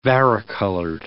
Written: varicolored

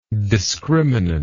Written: discriminantal

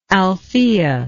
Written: althaea

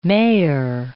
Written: mair

